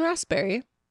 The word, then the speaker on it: raspberry: Do you pronounce the P in raspberry? No, I usually say raspberry. – Okay. – Like Raz with Z.